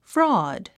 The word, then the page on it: fraud: frɔ́ːd; フロォード